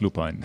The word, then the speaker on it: Lupine: Offiziell heißt es Lupine. Lupine selber sagt Lupine.